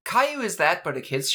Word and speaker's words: Caillou: That one, that's Caillou. – I googled K-I-W-Y-O-U, and then...